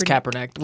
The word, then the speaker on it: Kaepernick: It's Kaepernick.